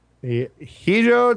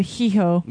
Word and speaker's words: hijo: Well, no, you said hijo. – Yeah. So that's why I want to say it. – That H is silent.